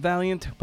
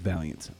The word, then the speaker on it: Vaillant: Remember, it's pronounced Vaillant, but it's spelled not like Vaillant. V-A-I-L-L-A-N-T.